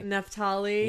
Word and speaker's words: Naphtali: Naphtali.